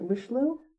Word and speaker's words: Richelieu: And it's A, Aramis, C, B, Cardinal Richelieu. Is it Richelieu? – Richelieu.